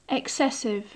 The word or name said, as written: excessive